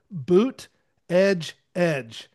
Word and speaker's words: Buttigieg: Never could figure out how to say his last name, and Buttigieg. – I was somewhere. Well, I saw somewhere somebody was wearing a shirt, and it said Boot Edge Edge.